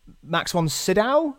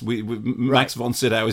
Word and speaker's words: von Sydow: I knew that Max von Sydow, is that his name? Is that how you pronounce it? – Yeah, I mean technically Max von Sydow, but yes, Max von Sydow is fine.